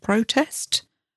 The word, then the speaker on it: protest: Notice the different emphasis on there. Protest and protest.